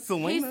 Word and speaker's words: Salinas: To a place called Salinas. – He said it how you should say it. – Literally, like, because it's spelled with a C. – Okay. – Salinas. And it's spelled just like that.